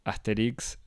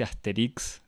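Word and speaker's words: Astérix: La primera es la que me parece la más urgente, incluso 60 años después de la aparición de, de Asterix Asterix se escribe con tilde en la E y se dice Asterix como si fuese una palabra aguda, o sea, con acento en la I.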